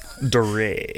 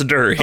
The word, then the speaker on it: Dury: That is Dury. Dury? I don't want to pronounce it wrong. I already did twice. – Dury. – Is it Dury or? – It's Dury.